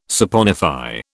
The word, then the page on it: saponify: British English